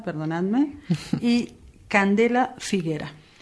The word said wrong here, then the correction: Figueira: Entonces, Figueira. – Figueira, perdón. Candela Figueira.